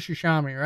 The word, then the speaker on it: Tsushima: So it says if Tsushima's, it's Ghost of Tsushima, right? – I feel like it's Tsushima. – It's definitely an S because it's pronounced with the S sound because my friend Aaron Jones, who's just a whiz at that kind of stuff, pronounces it something like that.